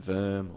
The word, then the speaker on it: Worm: pay homage to where they're from and how they pronounce their names, but the dude's name is Worm. – Yeah. – And we could call him Verm or just be nice.